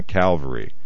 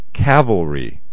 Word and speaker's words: cavalry: How do you say the word for men who are mounted and ride on horseback? It's cavalry. Cavalry. I know it sounds kind of like Calvary, but it's not the same.